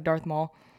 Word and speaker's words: Darth Maul: He looks like Darth Maul.